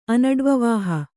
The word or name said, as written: ಅನಡ್ವವಾಹ